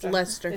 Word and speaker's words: Leicester: How do you pronounce this? – Leicester. – But. – I know. It's Leicester.